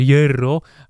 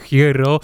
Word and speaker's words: Hierro: Aż nie mogłem złapać tchu, ale faktycznie Hierro, tak naprawdę czyta się Hierro, ale komentarz jaki znam z FIFA y, to jest jednak Hierro